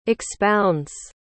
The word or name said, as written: expounds